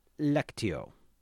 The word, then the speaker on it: lectio: It is a S sound because it has the little curly Q under the C, forgive me, which came from the Latin lectio. Lectio, long E, long O, which meant a reading, which in turn came from lectus.